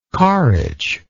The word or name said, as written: carritch